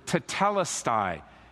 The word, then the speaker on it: tetelestai: It's the Greek word tetelestai.